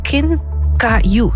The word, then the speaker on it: kinkajou: Het bleek te gaan om een rolstaartbeertje, ook wel Kinkajou, zeg ik het goed? Ja, genoemd.